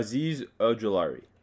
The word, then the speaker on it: Azeez Ojulari: Uh, I you know I also put a Leatherwood Richard Olujari. I can't say his name because I yeah I, I took a pick. I thought that Azeez, was uh, great. Uh, Azeez Ojulari.